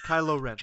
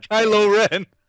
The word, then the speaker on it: Kylo Ren: I love the new bad guy, Rylo Kren. I think we can say his name, right? – Kylo Ren. – Kylo Ren.